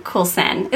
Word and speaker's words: Coulson: We are so excited to be here with Justin Coulson.